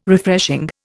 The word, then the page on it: Refreshing: Indian